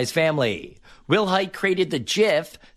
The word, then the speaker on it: GIF: However, back in 2013, Will Hite told the New York Times that he pronounces it with a soft G, making it GIF.